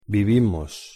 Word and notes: vivimos: Spanish

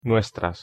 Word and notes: nuestras: Spanish

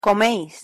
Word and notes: comeis: Spanish

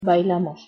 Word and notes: bailamos: Spanish

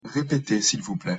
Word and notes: repetez sil vous plait: French

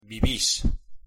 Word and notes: vivis: Spanish

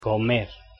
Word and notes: comer: Spanish

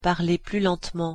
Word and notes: parlez plus lentement: French

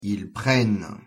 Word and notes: ils prennent: French

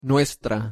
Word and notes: nuestra: Spanish

